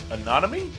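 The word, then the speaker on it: Anonyme: Anonymy? – There you go.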